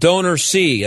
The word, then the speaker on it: DonorSee: And by the way, it's spelled Donor C as one word, D-O-N-O-R-S-E-E, as in you, the donor, see what you get for your money, right?